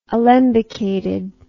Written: alembication

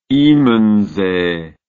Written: immensee

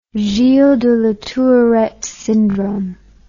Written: Gilles de la Tourette syndrome

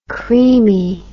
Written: creamie

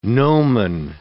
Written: gnomon

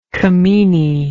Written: camenae

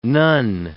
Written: nun

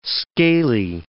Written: scaly

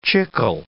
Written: chicle

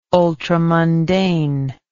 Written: ultramundane

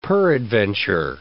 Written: peradventure

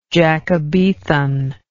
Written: Jacobethan

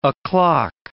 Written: o'clock

